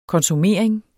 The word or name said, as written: konsumering